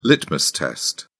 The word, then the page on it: litmus test: British English